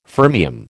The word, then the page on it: fermium: American English